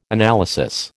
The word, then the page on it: analysis: American English